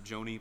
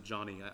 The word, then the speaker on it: Joni: You may have pronounced it Joni, but apparently she pronounces it Johnny.